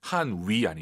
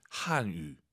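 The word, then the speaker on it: 汉语: 한위 아니고 입술 동그랗게 말아보세요. 동그랗게. 으, 으, 한유. 한유.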